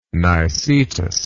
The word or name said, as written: nicetas